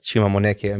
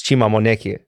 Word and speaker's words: Chima Moneke: correctly and honestly I'm not sure if I, am getting it 100% correctly but to me it's Chima Moneke